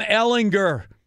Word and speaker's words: Ehlinger: Well, let me pronounce his name properly now. Sam Ehlinger.